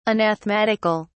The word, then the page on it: anathematical: British English